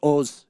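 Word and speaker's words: Oz: O's the Mentalist. O-Z is how you spell it. O's is actually how you pronounce it in Hebrew.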